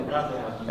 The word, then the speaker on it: anathema: But if any shall be found to be Judaizers, let them be anathema, or ana- anathema, how do you say that? – Anathema.